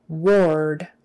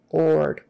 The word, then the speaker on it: ward: I know it's spelled with an A-R, so you would think it would be pronounced ward, R, but it's not. It's or. Ward. Ord.